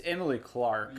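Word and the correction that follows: Emilia Clarke: Yeah, Amelia Clark. – Amelia Clark.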